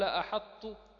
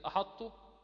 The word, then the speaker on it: أحط: كلمة أحط جاءت في سورة النمل في قوله تبارك وتعالى: فقال أحط بما. وفي سورة المائدة في قوله: بسطت إلي يدك. أخبر الناظم بأن أو نبه علينا بأن نبين صفة الإطباق في حال نطق هذه الطاء من هاتين الكلمتين.